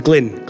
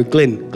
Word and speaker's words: Glyn: Hi, my name's Glyn. Glenn? No, Glyn. Glenn? No, Glyn. Glenn? How do you spell that? G-L-Y-N. G-L-E-N? No, G-L-Y-N.